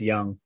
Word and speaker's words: Jung: It's spelled Jung. It's spelled like Jung, but I'm pretty sure it's pronounced Young.